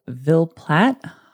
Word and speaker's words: Ville Platte: I tried to find it on YouTube to see if I'm saying it right, but it's V-I-L-L-E-P-L-A-T-T-E, thinking those E's are silent, so we're going to say Ville Platte.